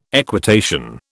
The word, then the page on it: equitation: British English